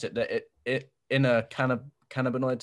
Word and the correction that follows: endocannabinoid: system. – Endocannabinoid system. – That's it.